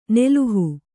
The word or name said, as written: ನೆಲುಹು